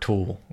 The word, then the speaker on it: tulle: That's some dark tulle. That's not your normal light-colored tulle. – Oh, you mean like T-U-L-L-E. – Did I pronounce it wrong? – No, that's right.